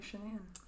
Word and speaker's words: Shanann: Shanann sounds like it's like trying to be Shannon. – This is how it's spelled if you want to look over at the computer. – Oh, yeah. – Like that. – It's literally Shanann.